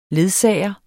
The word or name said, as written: ledsager